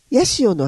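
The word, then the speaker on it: 八入: ヤシオの雨、ヤシオ、ハチ、ハチにいると書いてね、うん、ヤシオの雨って言うんですね、ハチ、うん、漢数字のハチとね、うん、で入るという字でヤシオ、これはね一雨ごとに木々を色濃く染めていく雨のことをヤシオの雨というそうですね。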